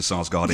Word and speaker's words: Sarsgaard: I am absolutely amazed that A, you know who Peter Sarsgaard is, and B, you pronounced his name correctly.